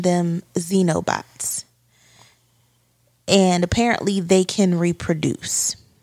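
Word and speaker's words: xenobot: So, um, apparently, there's a form of robot called Xenobots. Xenobots. Listen, it's spelled X E N O bots.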